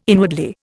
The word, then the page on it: inwardly: Indian